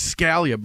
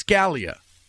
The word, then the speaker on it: Scalia: So it was Scalia. But my family, my father and mother, would say Scalia or Scalia or Scalia. They would butcher it. But it's Scalia. That's the American pronunciation. But the Italian pronunciation is Scalia.